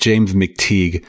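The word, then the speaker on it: James McTeigue: I believe it's pronounced James McTeague.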